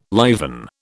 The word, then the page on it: liven: British English